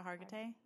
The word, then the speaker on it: Hargitay: But, like, the one that I always come back to is the lead on Law & Order SVU, Mariska Hargitay? – Yes, okay.